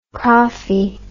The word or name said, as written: crawfie